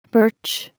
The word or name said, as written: birch